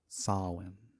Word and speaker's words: Samhain: called Samhain. – Samhain. – It's spelled Samhain, but pronounced Sowin. Samhain. – Mm-hmm. – Sounds like Lord of the Rings, Saruman.